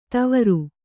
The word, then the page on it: ತವರು: Kannada